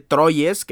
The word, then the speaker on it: Troyes: Troyes que perdió contra el Paris Saint-Germain o Troy como ahora eh, me acabo de enterar que se pronuncia.